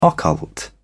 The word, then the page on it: occult: British English